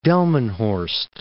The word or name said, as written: Delmenhorst